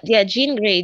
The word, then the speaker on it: Jean Grey: Jean Grey, J-E-A-N, then Grey.